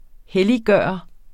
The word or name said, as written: helliggøre